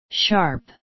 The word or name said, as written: sharp